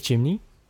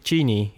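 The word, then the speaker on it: Cheney: Dick Chimney heet hij. Mhm. Oh, dat zeg ik niet goed. Of zeg ik dat goed? Ja, ja Chini. Ja, ik weet niet hoe je het precies uitspreekt. Ongeveer zo.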